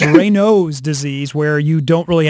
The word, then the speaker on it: Raynaud's: Found out you have I have something called Raynaud's disease. I just looked it up, and thank you on the text line because they were laughing at my Renard's disease.